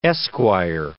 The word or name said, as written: esquire